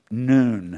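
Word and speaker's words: noon (ن): It's the consonant N, pronounced noon.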